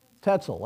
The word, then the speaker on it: Tetzel: Right after his conversion, how do we pronounce his name? Tetzel, that's it.